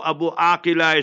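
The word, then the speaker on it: Abu Akleh: So Abu Aqila is the proper pronunciation.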